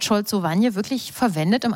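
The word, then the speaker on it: szolcowanie: Es gibt im Polnischen ein neues, relativ neues polnisches Wort, Scholzowanie. Ich hoffe, ich habe das richtig ausgesprochen.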